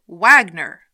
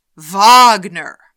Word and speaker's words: Wagner: The name was mentioned several times, and each time the host said, Wagner, like what a dog does with his tail. It's pronounced Wagner.